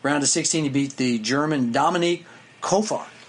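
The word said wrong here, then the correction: Köpfer: Yeah, Kepfer.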